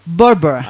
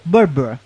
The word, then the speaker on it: Barbara: Barbara. – Ahí está. – Barbara.